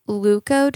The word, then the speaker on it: leuco: And usually the dyes that they use in these organic dye thermochromic mugs are what's called leuco dyes. I think that's how you pronounce it. It's L-E-U-C-O, leuco dyes.